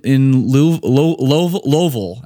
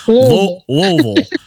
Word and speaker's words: Louisville: I think that's how they like to say it. – Louisville. – That's how they say it. I'm not making this up. It's like Louisville. – I know, yeah. – It's bizarre. – If you say Louisville, they'll kick you out.